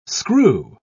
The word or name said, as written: screw